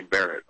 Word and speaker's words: Barrett: Yes, it's actually Barrett, like Brandon Barrett.